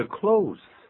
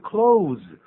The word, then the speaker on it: close: 所以 close 代表靠近的，做一个形容词。但如果念 close，这是一个动词哦。